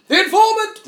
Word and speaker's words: The Informant!: You've got to say it like that. It's an exclamation point at the end. – The Informant!